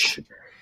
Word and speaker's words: Tecumseh: Shawn Esquire. That sounds about right. – I apologize. Tecumseh. For butchering that.